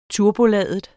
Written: turboladet

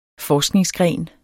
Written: forskningsgren

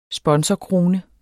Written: sponsorkrone

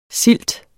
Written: SILT

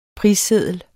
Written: prisseddel

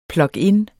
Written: plug-in

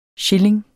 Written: shilling

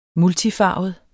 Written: multifarvet

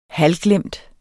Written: halvglemt

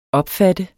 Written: opfatte

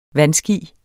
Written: vandski

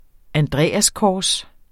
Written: andreaskors

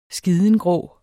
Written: skidengrå

